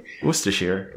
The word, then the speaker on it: Worcestershire: I was just going to say, pr- pronounce that uh, sauce again for me. – Worcestershire. There you go. You got it.